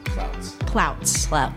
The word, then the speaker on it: Plautz: Plouts.